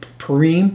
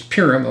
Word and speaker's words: Purim: Now, last week, some people call it Purim, because sometimes the I's are pronounced as an E, but it's Purim, okay?